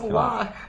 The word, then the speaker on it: Glock: It's just pronounced Glock. Like with Glock. A soft glottal stop.